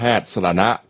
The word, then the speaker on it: สรณะ: ันเขาบอกว่าอ่านว่านายแพทย์สรณะครับแปลว่าที่พึ่ง